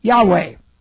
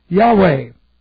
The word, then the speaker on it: Yahweh: Now, if we come down here, we go Y, A, H. No vowel here. W, A, H. Yahweh. Yahweh.